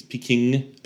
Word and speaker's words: Pekingese: It's Pekingese. Peking. Pekingese. – Pekingese. – Peking. – Pekingese. – Stop it. No, you're putting an extra N.